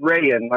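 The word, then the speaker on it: Rayen: But you can call my name Rayan, like the fabric, except E-N.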